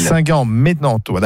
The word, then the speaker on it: Saint-Germain-en-Laye: C'est Saint-Germain-en-Laye, bref, c'est à côté de Lille. – Saint-Germain-en-Laye, d'accord.